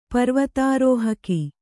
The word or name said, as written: ಪರ್ವತಾರೋಹಕಿ